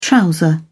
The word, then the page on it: trouser: British English